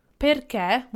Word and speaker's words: perché: Perché, with a graphic accent on the final E, it's used with the meaning of because.